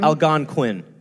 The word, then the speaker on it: Algonquin: So anyway, Thomas is getting into the Algonquian... am I saying it right? – Algonquin. – Quinn.